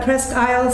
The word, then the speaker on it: Presque Isle: I know you're coming to do Presque Isle State Park."